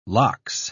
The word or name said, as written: lox